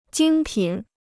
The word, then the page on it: 精品: jīng pǐn